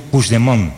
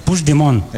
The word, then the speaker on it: Puigdemont: No, Puigdemont. – Puigdemont. – Vale. – Puigdemont. – Eso es. – Sí, lo dices como francés, así, tiene Puigdemont, como rollo.